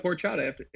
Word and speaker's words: horchata: H-O-R. – But you basically don't pronounce it. Yeah. – Horchata. – Nice. That's really good. – So it's like a silent H.